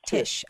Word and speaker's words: Tisch: Tisch? Is that how you say it? Tisch School of the Arts? – Tisch.